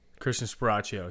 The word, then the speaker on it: Christian Sparacio: Christian Sparacio.